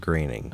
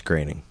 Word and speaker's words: Groening: It is indeed pronounced Groening.